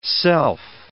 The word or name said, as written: self